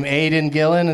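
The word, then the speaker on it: Aidan Gillen: Aiden Gillen, is that how you say his name? – Yeah.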